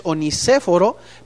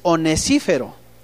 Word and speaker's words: Onesíforo: empiezan a ver milagros y probablemente Onesíforo cuesta trabajo pronunciar eso porque parecería que es onicéforo, pero es onesífero.